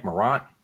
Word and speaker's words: Morant: How do you say his last name? Is it Morant? Morant.